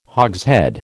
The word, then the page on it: hogshead: American English